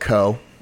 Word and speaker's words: Cough: Craig pronounces it co. – But so it's spelled cough.